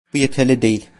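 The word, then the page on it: yeterli: Turkish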